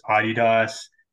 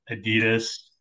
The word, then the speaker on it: Adidas: But fun fact, it actually technically is Adidas. That's the European pronunciation because the guy who founded Adidas, as we say in America, his name was Adolf Dassler, and so he shortened his name to Adi Dassler, and that's where the name Adidas comes from.